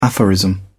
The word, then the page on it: aphorism: British English